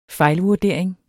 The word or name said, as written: fejlvurdering